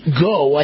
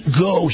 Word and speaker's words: Ngo: Andy Goh. I think that's how you pronounce it. The N is silent. N-G-O.